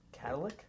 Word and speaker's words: catalytic: So he takes it in with his dad and finds out someone stole his, like, Cadillac converter. – Cadillac. He's been pronouncing it wrong. I told my dad about it, and he told me. – Catalytic? – It's either catalytic or catalytic. Not Cadillac, though.